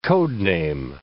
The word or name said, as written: code-name